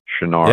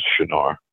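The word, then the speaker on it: Çınar: It's spelt out as C, the English transliteration would be C-I-N-A-R, I think, but there's a mark under the C in Turkish that would make it sound Shinar. – Yeah. – And that's how you pronounce it in Turkish, is Shinar.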